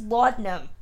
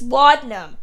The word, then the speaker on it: laudanum: So she's, you know, the accent aside, which she struggles with, and the pronunciation of that, it's laudanum, Elle. It's laudanum, not laudanum. There's not three syllables, it's two.